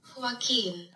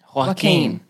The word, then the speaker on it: Joaquin: YouTube's about to pronounce it for us. – Guys. – Joaquin. – Joaquin. – Joaquin. – Nice, that's I think you nailed it.